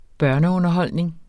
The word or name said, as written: børneunderholdning